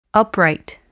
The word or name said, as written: upright